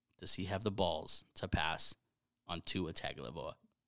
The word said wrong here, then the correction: Tagovailoa: I didn't say that right. – Tagovailoa. – Tagovailoa.